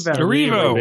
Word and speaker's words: Erivo: Erivo, baby, Erivo.